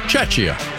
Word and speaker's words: Czechia: Game between South Korea and Czechia. I say it right? – Chechia. – Chechia? Right? I think I said that right.